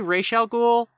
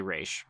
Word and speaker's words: Ra's al Ghul: Robin is abducted, and I'm gonna say Ra's al Ghul.